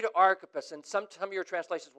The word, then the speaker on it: Archippus: He gets to the end of this list, and he has a message for Archippus. Am I saying that right? Yeah, I just want to check.